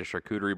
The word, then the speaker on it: charcuterie: But no, we were my folks, and I made a charcuterie board.